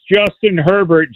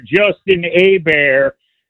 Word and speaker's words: Herbert: You had a bozo call about 10 minutes ago that pronounced Justin Herbert Justin Hebert, that doesn't understand head coaches coach to win this Sunday and don't look any further than that.